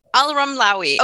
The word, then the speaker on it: Alramlawi: Alramlawi.